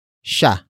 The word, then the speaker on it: siya: After that, we have sha, which is spelled as S I Y A.